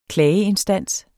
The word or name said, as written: klageinstans